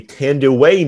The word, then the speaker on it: Thandiwe: Now, that misspelling has stuck all the way, for the last 30 years into 2021, and now she finally revealed that her name is actually Tanduay Newton, and it's spelled Thandiwe. There's a W right before the I and the E.